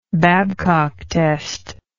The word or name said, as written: babcock test